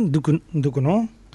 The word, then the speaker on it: Ducournau: Es eh, la peli Grave Crudo de Julia Duconau. ¿Esto cómo se pronuncia, en francés? Duconau. – Eh, Ducournau. Ducournau.